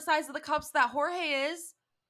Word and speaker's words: Jorge: We are live on iCarly, and there is a floating bra named George telling a ghost story. – It's pronounced Jorge, please.